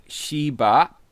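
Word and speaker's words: Xi Ba (十八): It's apparently pronounced something like Xi Ba, and it's considered...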